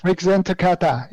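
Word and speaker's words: Rikuzentakata: Did I say it right? – Excellent. Rikuzen Takata. Yes.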